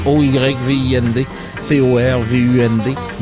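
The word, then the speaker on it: Øyvind Torvund: Alors allez voir sa biographie, ça s'écrit Oyvind Torvund, comme ça se prononce, Oyvind Torvund.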